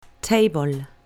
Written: table

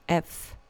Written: f

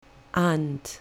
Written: aunt

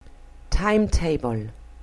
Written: timetable

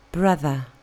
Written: brother

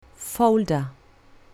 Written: folder

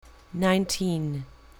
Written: nineteen

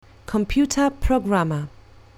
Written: computer programmer

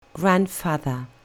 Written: grandfather

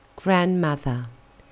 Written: grandmother

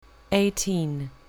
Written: eighteen

